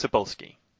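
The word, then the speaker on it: Cebulski: I've mispronounced his name on this podcast before. So I think it's Sebaluski. – Sebaluski. Okay, that sounds better. I was adding some syllables.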